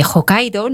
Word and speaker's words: Hokkaido: Igual que se desconoce otro perfil de islas que realmente están siendo emergentes y de hecho a la de Hokkaido,